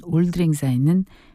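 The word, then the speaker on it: Auld Lang Syne: 다음 임시정부의 국가였던 올드랭사에는 스코틀랜드 민요로 알려져 있죠. 영어 올드 롱 신스의 스코틀랜드식 발음이라고 합니다.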